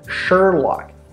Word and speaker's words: Sherlock: Sherlock. So the R is very strong, so Sherlock.